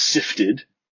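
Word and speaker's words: Siftd: It's S-I-F-T-D, which it is actually pronounced Sifted. – Okay. – Doesn't look like it, but that is how it's pronounced.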